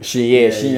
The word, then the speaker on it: chignon: It's probably pronounced like chignon. – Chignon. Yeah. That's what I was like. I think the G silent or some shit.